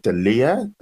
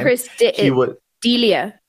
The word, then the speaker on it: D'Elia: And some of those include, like, you know, controversial guests, the founder of the Proud Boys in the US, the comedian Chris, is it D'Elia? That's how you pronounce his surname. – Chris D'Elia.